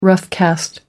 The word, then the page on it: roughcast: American English